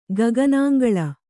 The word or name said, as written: ಗಗನಾಂಗಳ